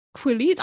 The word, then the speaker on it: Quileute: I'm going to look this up. Right. – Please. – Quileute. Quileute. Pretty straightforward. Once you know, Quileute. – Why does it sound like Jacob from Sex Education? Okay, Quileutes.